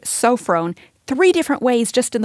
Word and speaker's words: sophron: The word here that's translated self-control in the English Standard Version is the Greek word sophron. Sophron. It's spelled S-O-P-H-R-O-N.